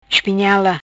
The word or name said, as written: Espinhela